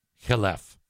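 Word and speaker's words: Imane Khelif: By the way, I got the pronunciation of that online, and that actually is correct. If you want to pronounce it like you're an American, you'd say Iman Khalif. But who wants to do that? She fought again over the weekend and won. Khelef.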